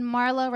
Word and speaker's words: Marla: And Marla rhymes with Darla, like from the Little Rascals.